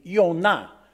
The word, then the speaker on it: Yonah: Well, my name in Hebrew would be Yonah, or as in the English Bible, it would be Jonah.